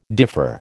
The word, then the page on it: differ: American English